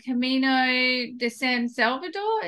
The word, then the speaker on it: Camino de San Salvador: And And I think it was the walk that you did on Camino de San Salvador.